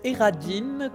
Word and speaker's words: Eradyn: Bonjour, Mestre Radin. – Alors, ça se prononce Eradine comme euh, Auberine ou Quentin.